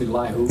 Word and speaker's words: Elihu: And then in Job 35:11, Because of the multitude, this is Elihu. Am I saying that right? – Norman's pronounced Elihu. – Elihu, okay. Elihu.